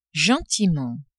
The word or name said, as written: gentiment